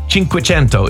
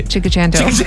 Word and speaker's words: cinquecento: Cinque is five and cento is 100. Cinquecento is how you actually pronounce it. – Cinque. Cinquecento. – Cento. No, don't. Don't. Say it your way. – Chicachento.